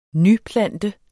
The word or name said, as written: nyplante